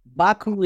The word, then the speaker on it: Baku: First of all, and you said that correctly, Baku is the center of our activities.